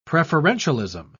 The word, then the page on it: preferentialism: British English